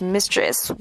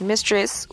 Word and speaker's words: mistress: Number eight，啊，第八个，如果是已婚男人的情人啊，我们就说这个婚外恋，我们通常会用这个 mistress 这个词啊，mistress，啊，注意一下这里的 e 的发音不要念作 f。念作 it 啊，mistress。同样给大家一个 example。 Picasso's painting of his mistress will go under the hammer at London. Picasso's painting of his mistress will go under the hammer at London.